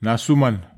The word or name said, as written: nasuman